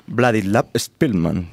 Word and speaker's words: Władysław Szpilman: basado en un libro homónimo y autográfico de Władysław Szpilman.